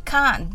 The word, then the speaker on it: can't: ら電話がかかってきたそうです緑茶はどうだったと聞いたら彼はこう言ったそうですああ美味しかったよでも I can't drink it without 〇〇〇〇なしでは飲めないね I can't は I can't のイギリス風の発音です。